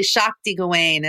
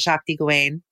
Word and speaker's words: Shakti Gawain: But can you tell us about Shakti Gawan and what it was like working with that incredible force of nature? – Yeah, it's actually Shakti Gawain is how you pronounce it, Shakti Gawain.